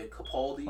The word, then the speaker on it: Capaldi: Louis Capta. I can't even pronounce it. – Louis Capaldi. – Yeah, Capaldi.